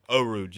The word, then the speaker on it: Eau Rouge: especially into however you pronounce Elon Rouge. You want to take that? I know you're better at pronouncing stuff than me. – Eau Rouge, yeah.